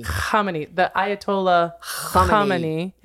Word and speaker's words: Khamenei: You're going to have to help me with the pronunciation. It looks so much like Khomeini. Khamenei. Let me get his name. – Khamenei.